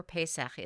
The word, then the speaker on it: Pesach: Passover, or Pesach as it's sometimes pronounced, is a time when family and friends gather to have a service and a meal called a Seder.